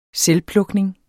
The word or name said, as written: selvplukning